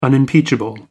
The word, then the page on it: unimpeachable: American English